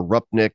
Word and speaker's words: Rupnik: I think that's like uh, Rupnik or Rupnik. I'm not certain how, how do you pronounce it? Do you know? Uh, yeah, I believe it's Rupnik.